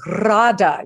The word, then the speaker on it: RADA: RADA. You have to say it like that with the twirling R, right? – You do indeed, exactly.